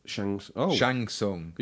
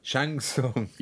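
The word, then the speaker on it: Shang Tsung: Chang-Chung and Nightwolf are out now. So if Mortal Kombat fans have no idea what I'm talking about, it's probably because I pronounced it wrongly. – Shang-Chung. – Shang-Tsung. You're having a go.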